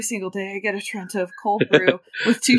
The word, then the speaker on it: Trenta: They also don't have Trentes over there. – Oh, I said it wrong, didn't I? Trenta. – Trenta? Yeah, Trenta, with an A.